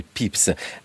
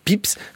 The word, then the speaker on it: Pepys: qui joue le rôle de Samuel Pepys. Ça s'écrit Pépis, hein, si on voulait prononcer à la française. Alain Potofsky, qui est-il ce Pepys?